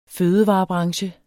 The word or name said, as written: fødevarebranche